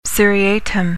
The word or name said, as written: seriatim